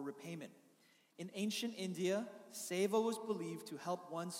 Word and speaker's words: Seva: Seva.